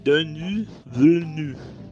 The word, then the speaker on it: Denis Villeneuve: E o único cara que fez o Dave Bautista atuar de verdade na carreira dele até agora foi o Denis Villeneuve. Você sabe uhum. – pronunciar? – Denis Villeneuve.